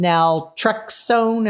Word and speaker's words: naltrexone: All that to say, you mentioned LDN, the low dose naltrexone. Am I saying that right? – Yeah, LDN.